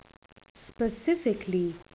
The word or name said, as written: specifically